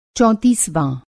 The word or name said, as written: चौंतीसवाँ